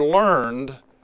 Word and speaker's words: learned: And that word learned, we just say it with different emphasis on a different syllable, and we say learned. Jesus Christ was not a learned man.